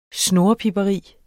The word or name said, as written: snurrepiberi